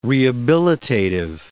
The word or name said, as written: rehabilitative